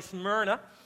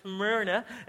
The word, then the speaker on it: Smyrna: Everybody say Smyrna. You guys say it like that, Smyrna.